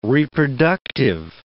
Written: reproductive

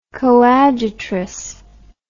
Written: coadjutress

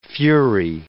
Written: fury

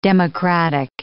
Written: democratic

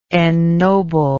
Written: ennoblingly

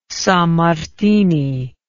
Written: Sammartini

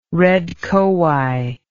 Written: red kowhai

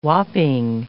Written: whopping

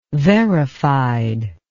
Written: verified

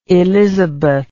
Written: elisabeth

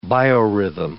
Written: biorhythm

